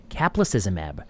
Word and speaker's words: Caplacizumab: This is about a novel drug Caplacizumab. Did I pronounce that correctly? You did. Caplacizumab for the treatment of acquired TTP,